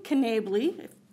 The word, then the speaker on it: Knabley: I would like to point you to the language in Knabley, that's how it's pronounced, referencing that the potential use of the firearm by a convicted felon is the concern, okay?